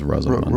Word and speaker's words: Rosamund: Rosamund Pike? – What is it? Rosamund? – I think it's Rosamund. – Rosamund Pike, yeah.